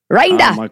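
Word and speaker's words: Rinder: We got to meet Mike Rinda. Did I say that wrong? – That's right.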